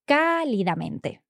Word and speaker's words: cálidamente: So it has an accent on the first A, cálidamente.